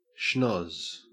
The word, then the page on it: schnozz: Australian English